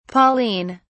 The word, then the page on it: pauline: British English